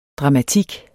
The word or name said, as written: DRAMATIK